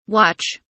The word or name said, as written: watch